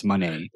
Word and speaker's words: Moné: And it is from NJPW Battle of the Valley between Mercedes Moné. How do you pronounce the last name again? – Moné. – Moné. – Like Moné with the arte.